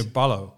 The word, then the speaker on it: Paulo: And yeah, I heard it's that the correct pronunciation for Junior Paulo yeah, is Junior Ballo.